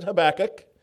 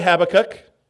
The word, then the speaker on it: Habakkuk: Please turn with me over to, I pronounce it Habakkuk. You may say Habakkuk. I learned the wrong way when I was 4 and I haven't changed.